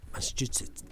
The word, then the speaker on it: Massachusetts: ¿No me dice Massachusetts de vuelta? – Massachusetts. – Qué bien que pronuncia, muy bien.